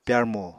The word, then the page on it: pyarmo: Apatani